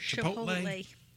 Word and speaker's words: Chipotle: Chipotle.